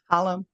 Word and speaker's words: Holem: Holem.